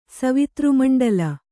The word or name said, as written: ಸವಿತೃಮಂಡಲ